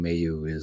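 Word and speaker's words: Mailloux: So we all have our slants on language, but just, I see a lot of people saying Mayu and it's Logan Mayu is the correct pronunciation on him.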